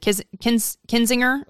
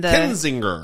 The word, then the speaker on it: Kinzinger: So it's the censure of Cheney and Kinzinger.